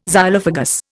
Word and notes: Xylophagous: Indian

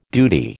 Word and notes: duty: American English

